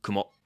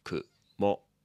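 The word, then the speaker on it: Kumo (くも): Kumo. Kumo.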